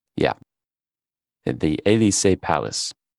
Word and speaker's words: Elysee: Oh, sorry. That's just totally horribly pronounced on my part. I didn't try to do this ahead of time. Let me think about it in my head for a second. Elysee. That's what it is. E-L-Y-S-E-E.